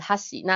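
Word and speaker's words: 箸: 那最好是有一个，就是、嗯、呃，老师可以纠正你的发音，或者是说你利用一个有音轨的教材，那持续的跟着他念，而不是说啊，我要去记这个筷子的发音哈西，那它的重音是在哈上面，然后那个。牡蛎的重音是在卡基，在卡上面，不是这样子记哦，就是只要你重复的一直利用音轨来聆听的话，那你就自然而然的容易记起来这个发音这样子。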